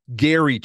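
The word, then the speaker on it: Gerry: So they come up with the idea of the Golden Bachelor, and it's Gary Turner, I'm told, even though it's spelled in the Jerry, they call him Gary.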